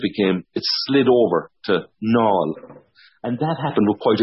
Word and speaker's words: Naul: they just spelt it N-A-U-L, Nall, because that's how the locals would pronounce it.